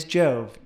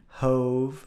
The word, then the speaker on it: Jove: The who is me, Jove Meyer. That's right. My first name is Jove, J-O-V-E, spelled sort of like love with a J, but not necessarily pronounced that way. I've also been called Jovi, Hove, Hove, and a series of Jove-ish pronunciations in that manner.